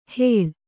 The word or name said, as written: ಹೇಳ್